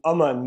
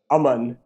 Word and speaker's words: Aman: I've switched it from Aman to Aman more recently, which is two short A's, short syllables. But I'm also fine with Aman.